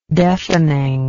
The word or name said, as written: deafeningly